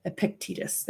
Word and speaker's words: Epictetus: I don't think I'm saying that right. Epictetus, there we go.